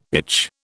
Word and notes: itch: British English

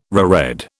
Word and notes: reread: British English

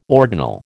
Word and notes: ordinal: American English

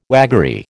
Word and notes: waggery: American English

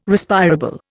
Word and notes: Respirable: Indian

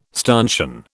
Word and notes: stanchion: British English

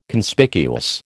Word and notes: Conspicuous: American English

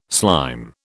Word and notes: slime: British English

